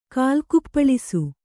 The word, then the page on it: ಕಾಲ್ಕುಪ್ಪಳಿಸು: kannada